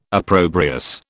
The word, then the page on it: opprobrious: British English